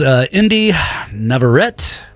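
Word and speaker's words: Inde Navarrette: Indy Navarrete.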